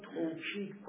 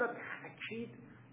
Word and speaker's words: توکید: ما در فارسی خیلی تأکید میگیم لغت رو، چون توکید کلن مقدار سخت در تلفظ بر فارس ها، تأکید بیشتر به کار داریم. ولی تأکید لغتا لغت ظاهرا غلطیه، توکید درسته،